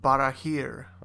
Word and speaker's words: Barahir: It was named the Ring of Barahir.